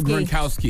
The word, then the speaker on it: Gronkowski: Rob Gronkowski.